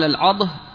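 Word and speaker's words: العَضْه: والعضه يقال العضه، أي بفتح، يقال بفتح المهملة وسكون المعجمة، وهكذا هي في كتب الحديث، في كتب أهل الحديث، وفي كتب غريب اللغة تنطق العضة بكسر العين وفتح الضاد.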